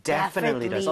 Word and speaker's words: definitely: 자, 그런데 강세를 여러분들 이쪽에 줘보는 거예요. 이렇게 하게 되면 definitely 이렇게 앞쪽에 강세가 가면서 뒤에 틀리라고 할 여유가 안 생기게 돼요. – 네. – 그렇게 돼서 definitely 이런 이상한 발음이 아니라 definitely 이렇게 발음이 될 수밖에 없습니다.